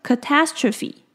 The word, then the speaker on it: catastrophe: 像名词的部分呢，它的重音是在 TAS 的部分，所以它念作 catastrophe。